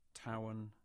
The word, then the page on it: Towan: English